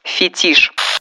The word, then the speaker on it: фетиш: Фетиш. Слово пришло из французского языка, где ударение в существительных падает на последний слог.